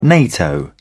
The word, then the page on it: nato: British English